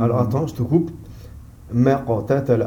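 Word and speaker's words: ما اقتتل: Alors, mais la prononciation ici, elle s'annule. Parce qu'on a le hamzat wasal. Donc lui, quand on a ce alif là, il est muet et il annule la prononciation qui le précède. Donc ما قتل.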